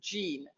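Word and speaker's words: Gênes: toile de Gene, that you pronounce Jean.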